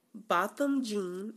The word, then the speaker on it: Botham Jean: So this story is about um Botham Jean. Am I saying that right? That sounds about right.